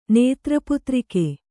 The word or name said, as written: ನೇತ್ರಪುತ್ರಿಕೆ